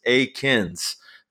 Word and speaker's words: Eakins: And he's like, "Hey, it's Aiken, like bacon."